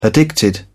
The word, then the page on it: addicted: British English